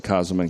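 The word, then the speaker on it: Käsemann: First, Kaseman, that's Kaseman, and some people say Kaseman, some people say Kaseman. I say Kaseman because it's closer to the way Germans pronounce it and the way Jürgen Moltmann pronounced it.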